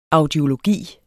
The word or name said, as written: audiologi